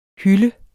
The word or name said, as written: hyld